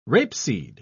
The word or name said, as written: rapeseed